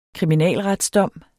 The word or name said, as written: kriminalretsdom